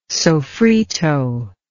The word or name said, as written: soffritto